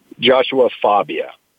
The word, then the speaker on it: Joshua Fabia: Joshua Fabia.